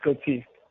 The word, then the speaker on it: Gauthier: It's everyone goes by Gauthier, but I'm French-Canadian, so it's Gauthier.